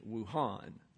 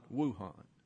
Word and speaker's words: Wuhan: By the way, the correct pronunciation of that town is Wuhan, not Wuhan. That's Merriam-Webster's Geographical Dictionary.